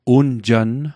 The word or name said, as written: unjann